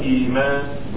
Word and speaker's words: Iman: Iman spelled like this. I-M-A-N. But sometimes that doesn't allow you to know how to pronounce it correctly. But the correct pronunciation of this word is Iman. Iman, an Iman.